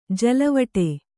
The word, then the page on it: ಜಲವಟೆ: Kannada